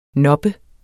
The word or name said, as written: noppe